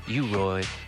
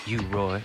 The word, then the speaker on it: U-Roy: U-Roy, the great U-Roy, or Hugh, H-U-G-H, Roy as it's spelled on this 45, Flashing My Whip.